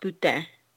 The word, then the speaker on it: poutine: Excuse you, it's pronounced poutine.